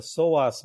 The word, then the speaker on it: psoas: The second one is a muscle called the psoas muscle. It is spelled PSOAS.